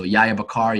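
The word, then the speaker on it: Yahya Bakkar: So Yahya Bakar, you can find me, stalk me there, and feel free to send me a message.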